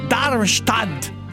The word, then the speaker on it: Darmstadt: a Darmstadt.